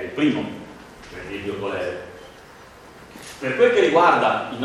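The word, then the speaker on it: cholerae: Allora, la specie più importante, più nota, è quella del Vibrio colere, si dice colere, perché il genitivo è del colera, anche se molti lo pronunciano Vibrio colera, non è che è importante che voi sappiate che si chiama così.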